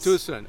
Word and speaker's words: Tucson: tell me how to say it. – The Hyundai Tucson event, yes. – Tucson, okay. – Yes. – And then, there, in Tucson. – There you go, you're on it.